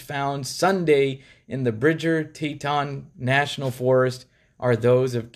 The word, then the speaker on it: Teton: September 19th, human remains were discovered by the FBI during a search of Spread Creek Dispersed Camping Area in Wyoming's Bridger Teton National Forest. I said it right? – Teton? The Grand Tetons. – Oh, Tetons.